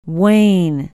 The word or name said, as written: wayne